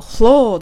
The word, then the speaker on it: floor: フロアというよりも floor って発音していますよね。 The kitchen floor. という感じで発音がされています。